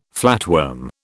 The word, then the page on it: flatworm: British English